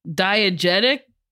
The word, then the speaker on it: diegetic: Well, I'm glad you had that one, because diegetic? Oh, did I say it right? – Yeah.